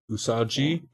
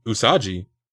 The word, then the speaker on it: Usagi: Okay, so U as in udon. Let's see, rhymes with blue. So U is right, and then sa like soccer, and then G. Oh, it's G. Rhymes with B. – G. Usagi.